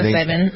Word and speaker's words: psilocybin: Is it psilocybin? Am I pronouncing it right? – Yeah, psilocybin. Mm-hmm.